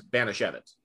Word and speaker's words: Banashevitz: If you look at the name, you're like, I have no idea how to say this, but if you hear it, it's Banashevitz.